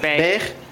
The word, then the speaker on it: Beghe: B-E-G-H-E. How do you pronounce that? – Beige.